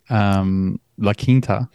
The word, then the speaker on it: La Quinta: That's it, La Quinta. La Quinta, yeah. as it's properly pronounced.